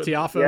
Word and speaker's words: Tiafoe: I I think the bigger story is what, what, TFO is that how you pronounce his yeah. – name? – Yeah, T- Tiafoe, yeah.